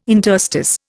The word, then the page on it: interstice: Indian